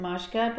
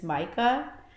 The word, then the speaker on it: Majka: However, we pronounce it Majka, but like the actual word, like right way of pronouncing it, like in Serbian, it's Majka.